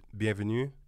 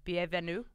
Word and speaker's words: bienvenue: So welcome is bienvenue. – Bienvenue.